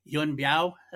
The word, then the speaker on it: Yuen Biao: Yuen Biao, that's what I say his name. Mm-hmm. It is.